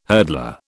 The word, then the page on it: hurdler: British English